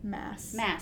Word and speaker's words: Maas: Maas that she, or Maas, how do you say her name? – Maas.